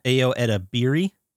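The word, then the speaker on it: Ayo Edebiri: It has A.O. Edebrini from, sorry, A.O. Edebiri. I believe is how you pronounce it.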